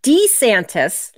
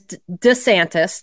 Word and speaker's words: DeSantis: So supposedly there's DeSantis, like with the long E, there's DeSantis, just kind of like, you know, not putting the emphasis on it. And then there's DeSantis, which I prefer DeSantis.